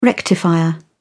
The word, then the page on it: rectifier: British English